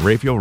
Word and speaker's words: Raphael: St. Raphael, Raphael. – Raphael. – Raphael. – The native, I'll say Raphael. – No, locally, it's pronounced Raphael.